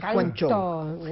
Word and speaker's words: Guangzhou: Tenemos hoy acá con nosotros a un experto, Mario Quinteros, que fue cónsul argentina en Guangzhou, no sé si lo dije bien. – Acá decimos vive... Cantón, ¿no? – Pero Guangzhou. Guangzhou.